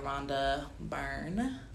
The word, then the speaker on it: Rhonda Byrne: I don't know if you guys have ever seen this documentary, but it's also a book um, called The Secret by Rhonda Byrne.